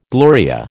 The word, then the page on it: Gloria: American English